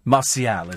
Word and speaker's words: Martial: I think that's how you pronounce it. It's Martial, is it? Oh, right. Martial, M-A-R-T-I-A-L, is now pronounced Martial, is it?